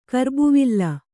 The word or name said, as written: ಕರ್ಬುವಿಲ್